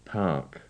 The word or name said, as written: park